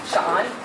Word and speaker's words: Sean: So my name, Sean, is Welsh. I could tell you a lot of funny stories about people that have mispronounced it. My favorite one that my kids love is Stan. I think sometimes people see the I and like put the T in there.